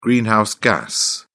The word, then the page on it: greenhouse gas: British English